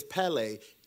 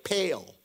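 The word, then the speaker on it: pele (πάλη): For we wrestle not, and the word wrestle, he uses the word as pele. When you look at it, it looks like pale, but it's pronounced pele.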